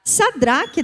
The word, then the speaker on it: Sadraque: Mas Cedraque, Mesaque Estou falando certo? Sedraque, Mesaque e Abednego. Como é que é? Sadraque. De onde que eu estou falando isso? É que eu escrevi, tá vendo? Escrevi errado, falei errado. É Sadraque.